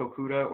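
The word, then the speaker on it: Okudah: I have Detroit taking Okuda. Sorry, I don't know how to say it. – Okuda, yeah.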